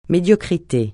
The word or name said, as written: médiocrité